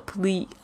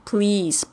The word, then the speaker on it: please: L 应该是的抵抵住这个牙齿前排的后方哦，应该不是要吐出来这个 please，而是 pl pl pl 这个声音。 Please，不要念成 please 或者 police，这个都是错误的发音。